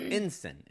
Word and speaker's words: ensign: 01 is actually pronounced ensign, is what it would be called.